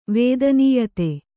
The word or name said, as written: ವದಾನ್ಯತೆ